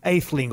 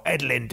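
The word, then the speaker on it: Ætheling: But we're also talking about his three sons and his grandson, William Aetheling, or Edlin, depending on how you pronounce it.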